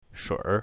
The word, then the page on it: shuir3: mandarin